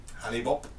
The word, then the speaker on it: Hale-Bopp: Hale-Bopp. – Hale-Bopp, is that how it's pronounced? – I prefer Hale-Bopp.